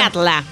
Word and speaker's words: Katla: Katla.